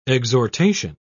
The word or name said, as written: exhortation